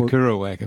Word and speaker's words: Kerouac: The Kerouac effect, yes.